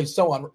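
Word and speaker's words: Sochan: By the way, Sohan, it rhymes with Zohan. Remember Zohan, your favorite Adam Sandler movie?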